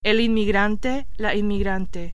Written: inmigrante

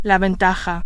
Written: ventaja

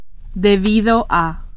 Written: debido a

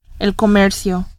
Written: comercio

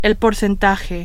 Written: porcentaje

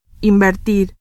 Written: invertir

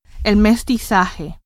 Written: mestizaje

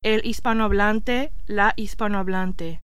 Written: hispanohablante

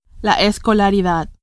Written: escolaridad

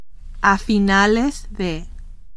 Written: a finales de

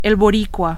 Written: boricua

